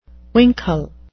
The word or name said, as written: winkle